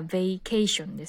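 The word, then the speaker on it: vacation: 正確にはベイケーションですよね。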